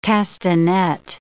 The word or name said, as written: castanet